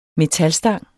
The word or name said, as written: metalstang